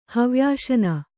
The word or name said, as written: ಹವ್ಯಾಶನ